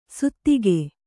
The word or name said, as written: ಸುತ್ತಿಗೆ